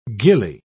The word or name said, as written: gilly